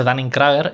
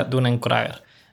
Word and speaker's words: Dunning-Kruger: Hay un efecto, se llama efecto Dunning-Kruger, o Dunning-Kruger, o sea, se escribe Dunning-Kruger, yo siempre lo he dicho Dunning-Kruger, y luego escuché que se decía Dunning-Kruger.